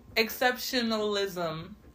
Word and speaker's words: exceptionalism: this is a black exceptionism. Did I say it right? I think I didn't say it right. A black exceptionalism problem.